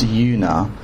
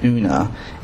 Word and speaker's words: Una: Her name is pronounced Una, not Una.